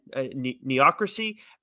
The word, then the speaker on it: neocracy: Can Cable root out the growing threat and decimate it before, again, neocracy as a chance to take hold? – Neocracy would be the way that it is pronounced, Nicholas, sort of like bureaucracy.